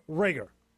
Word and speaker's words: Reagor: I'm probably going to say his name wrong. Jalen Reagor. – Rager. – Rager.